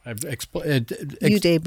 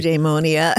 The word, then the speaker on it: eudaimonia: That is it eudaimonia or eudaimia? – Eudaimonia. – Pronounce it properly. – I say eudaimonia. Okay. I mean, but there are disagreements.